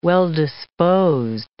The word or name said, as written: well-disposed